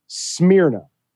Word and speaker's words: Smyrna: S-M-Y-R-N-A.